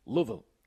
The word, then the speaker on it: Louisville: See, you're messing the last part. It's Vol. – Vol. Yeah. Louisville. – No, Louisville. – Louisville. – There you go. You got it on the last one.